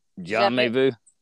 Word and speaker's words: jamais vu: Just like with how how do you say it? Jam jam jamais vu. Jamais vu.